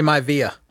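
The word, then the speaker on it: Maiava: Number 9, Jade Mavia, or is it Miavia? How do you say his last name? – I say Mavia. – Mavia, but there's no I-A. It's M-A-I-A-V-A. – That's close enough to Mavia.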